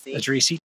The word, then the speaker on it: Addrisi: Addrisi.